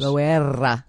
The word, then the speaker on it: Loera: Do I have to roll my R's? – You're supposed to roll your R's. – Loera. – Better.